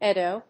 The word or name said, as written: Eddoe